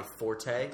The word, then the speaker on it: forte: So everyone says, this is my forte, like meaning my strength, right? But it's a French word. It's pronounced fort. Fort. The E is silent.